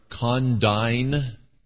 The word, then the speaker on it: condign: And these two words are condign and congruous. Condign, spelled C-O-N-D-I-G-N, it has a silent G in that word, comes from the Latin word condignus, and it means adequate.